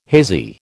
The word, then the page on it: hazy: American English